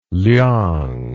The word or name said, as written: liang